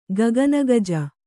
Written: ಗಗನಗಜ

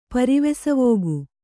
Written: ಪರಿವೆಸವೋಗು

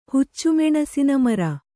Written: ಹುಚ್ಚುಮೆಣಸಿನಮರ

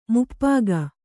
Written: ಮಾಪಕ

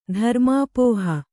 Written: ಧರ್ಮಾಪೋಹ

